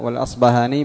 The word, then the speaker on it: الأصبهاني: قال الراغب الأصفهاني، يقال الأصفهاني بالفاء والأصبهاني بالباء، وجهان صحيحان.